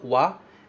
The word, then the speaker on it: wa: Antes da gente entrar, de fato, nos detalhes de cada uma, preste bastante atenção que essa partícula wa, ela se escreve com hiragana ha, mas ele é sempre pronunciado como wa se ele estiver com papel de partícula, certo?